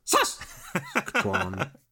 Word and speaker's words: Saskatchewan: Emphasis is on the first syllable, apparently. Saskatchewan.